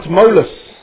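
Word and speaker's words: Tmolus: Mount Molus, and you spell it with a T, by the way.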